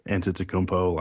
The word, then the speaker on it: Antetokounmpo: real life, like Giannis Antetokounmpo.